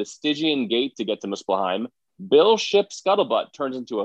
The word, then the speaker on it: Muspelheim: Odin sends the three to Muspelheim. Am I pronouncing that right, Clark? Muspelheim? – I believe so, yeah.